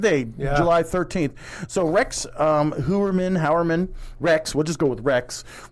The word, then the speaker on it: Heuermann: So a Rex Heuermann, is that how they are pronouncing it? – Heuermann.